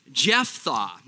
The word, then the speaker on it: Jephthah: Now, say Jeph. It's the PH kind of F sound. Jephthah, like Jeph's thawing out. That's how we're going to pronounce it. I'm going to try to pronounce it that way.